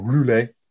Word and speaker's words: Roulers: Uh, and the feeling was if you could capture that at Roeselare, or Roulers, uh, as it was known, if you're going to do the French pr- uh, pronunciation, um, then you could effectively, effectively cut the German army off.